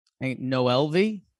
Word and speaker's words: Noelvi: How do you pronounce his name? – Noelvi. – Yeah, just straight up Noelvi.